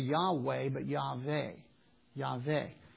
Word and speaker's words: Yahweh: By nearly all unanimous consent and based on the available evidence that we have, it is believed that the divine name was pronounced either Yahweh or Yahweh, depending on whether you took the W and pronounced it like a V.